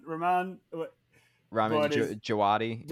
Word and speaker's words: Djawadi: Ramin Djawadi. – Djawadi, yeah, there you go.